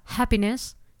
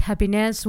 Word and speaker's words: happiness: Y no es tan fácil como parece pronunciarla bien, porque la palabra happiness lleva el acento en ha, pero casi no da tiempo a hacer ese acento, hay demasiada letra.